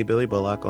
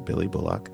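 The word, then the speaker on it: Billie Bullock: You can find me on the internet at Hey Billy Bullock on Instagram. That's spelled BILLIEBULLOCK. It's my name, Billy Bullock.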